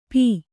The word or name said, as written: ಪೋ